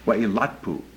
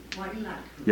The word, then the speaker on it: Wailatpu: Wailatpu. – Wailatpu. – Yeah. – Okay, third syllable. Third syllable.